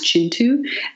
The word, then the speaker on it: Kintu: Oh, and Jennifer Makumbi's Chintu, which is spelled K-I-N-T-U, but is pronounced Chintu, is a Ugandan epic.